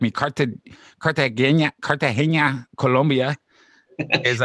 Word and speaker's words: Cartagena: All the way from Cartagena, Colombia. – And say like Cartagena with an N-Y, but it's Cartagena.